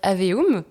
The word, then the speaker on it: Aveum: récemment, il y a un jeu qui est sorti et tu fais donc Jack, dans le jeu, attention, est-ce que je vais bien le prononcer Immortals of Aveum. Ah là là. C'est exactement ça.